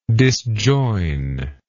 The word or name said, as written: disjoinable